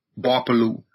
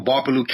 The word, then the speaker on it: Gbarpolu: So my county is referred to as Barpalu, spelled with a G. The G is silent. G-B-A-R-P-O-L-U, Barpalu. So Barpalu County.